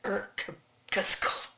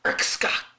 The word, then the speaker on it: Irkutsk: So then we get to the next place, and it's Irkutsk. That's how you say it. Irkutsk.